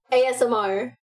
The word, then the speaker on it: ASMR: ASMR.